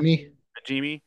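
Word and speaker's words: Najimy: Nahimi. – Kathy. – Yeah. – Nahimi or whatever. Najimi.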